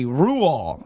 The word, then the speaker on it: Roald: I did a quick Google and it says the correct pronunciation of his name is actually Ruol, and the D at the end remains silent as the grave. I don't know what that means. – Ruol.